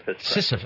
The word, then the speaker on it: Sisyphus: Sisyphus.